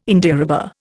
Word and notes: Indiarubber: Indian

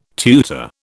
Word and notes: tutor: British English

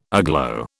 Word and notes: aglow: British English

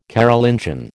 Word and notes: Carolingian: American English

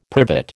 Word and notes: privet: American English

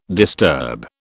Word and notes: disturb: British English

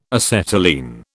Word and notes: acetylene: British English

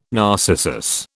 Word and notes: narcissus: British English

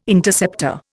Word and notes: Interceptor: Indian

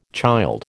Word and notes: child: American English

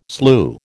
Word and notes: slue: American English

